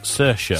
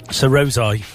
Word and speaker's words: Saoirse: How'd you pronounce that? – Saoirse. – Saoirse Ronan. – I'm glad he didn't have to read that. How would you have read that? – Sir Rosai. – Saoirse.